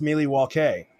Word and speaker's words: Milwaukee: Actually, Bradley, it's pronounced Milwaukee, which is Algonquin for the good land.